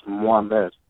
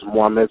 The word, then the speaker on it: Mohammed: So it happened because my first name is actually pronounced Mohammed, so it's Mohammed Sanogo, but a lot of people have a hard time with that.